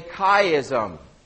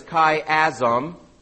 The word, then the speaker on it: chiasm: This is known as a chiasm. Now, that's how you say the C-H-I there. The writing style that uses a unique repetition pattern for clarification or emphasis. A chiasm is pronounced as chiasm,